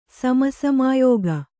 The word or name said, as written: ಸಮಸಾಮಯಿಕ